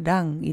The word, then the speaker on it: 랑: 사 랑 这个字呢，虽然很简单，不过在念的时候，那个 랑 一定要记得是嗯嗯嗯，收一个嗯嗯嗯的，收一个一样的发音。如果你嘴巴不小心闭起来的话，就会变成 사 람 사 람 사 람 就是人哦，但是我们不要忍，我们要爱，所以是 사 랑。